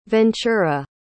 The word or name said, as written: ventura